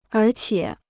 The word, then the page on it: 而且: ér qiě